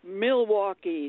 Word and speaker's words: Milwaukee: so many people mispronounce the city Milwaukee. It's M-I-L. So many people will say Milwaukee, M-A-U, or M-U-L.